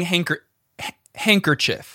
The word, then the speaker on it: handkerchief: It's spelled that way, but we don't say handkerchief. We don't. – You can, there's no silent D.